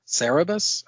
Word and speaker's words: Cerberus: Is it Cerberus or Cerberus, the aardvark? – Yeah, it's misspelled, so the Dave Sim character, you I think it's pronounced Cerberus, I thought.